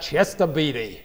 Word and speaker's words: Chester Beatty: Right? That's how you should pronounce that. Chester Beatty.